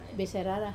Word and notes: sairara: wamesa

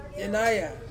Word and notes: yenaia: Ansus

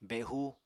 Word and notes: behu: Apatani

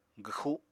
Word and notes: gwxu: apatani

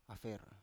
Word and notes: afer: Biak

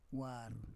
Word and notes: war: Roon

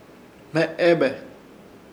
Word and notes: Hmeebe: Ishir Chamacoco

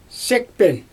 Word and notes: sikpen: Xyzyl

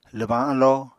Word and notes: lwbañ alló: Apatani

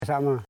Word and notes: sama: sa.ma

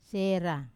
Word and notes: sera: Wamesa

